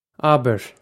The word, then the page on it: abair: Irish